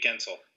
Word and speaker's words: Guentzel: Every year, like here comes how do you pronounce Getzel? Getzel, Jake Guentzel.